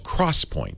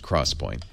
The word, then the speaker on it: XPoint: Intel has a new memory storage technology called CrossPoint. And the cross is an X. I couldn't make this stuff up if I tried. So it looks like XPoint, but it's pronounced CrossPoint.